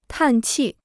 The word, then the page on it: 叹气: tàn qì